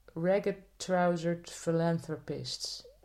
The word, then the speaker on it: Ragged Trousered Philanthropists: En dat heette de Ragged Trousered Philanthropists.